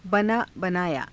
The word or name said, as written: banaa banaayaa